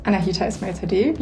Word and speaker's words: Anahita Esmail-Sadeh: Also mein Name ist Anahita Esmail-Sadeh, wie du es gerade perfekt ausgesprochen hast.